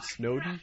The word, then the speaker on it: Snowden: All right, so for people who don't know, there's this guy, Edward Snowden. Did I pronounce it correctly? – Mm-hmm. Yes.